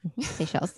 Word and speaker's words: Seychelles: Seychelles? – Oh, the Seychelles. – Thank you. Seychelles.